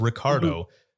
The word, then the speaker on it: Ricardo: I think Frederico once, and I don't even know how they got that one, but I believe it's because when I would say my name, I would say it fast. So I would say Elegant Central, this is Ricardo. So I don't think they would hear the R part.